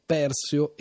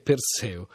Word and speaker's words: Perseo: Non si tratta, insomma, di uno di quei casi in cui la scelta è tra la pronuncia alla greca e la pronuncia alla latina, come invece rispettivamente in ossimoro e ossimoro, flogosi e flogosi, perseo e perseo.